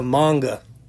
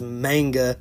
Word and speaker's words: manga: Or if you're that big of a fan, it'll make you want to read the manga. And I know most people mispronounce it as manga, but it's actually pronounced manga.